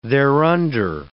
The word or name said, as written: thereunder